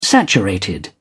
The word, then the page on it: saturated: British English